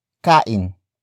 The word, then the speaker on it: kain: It comes from the root word kain. Kain. Kain. Which means eating.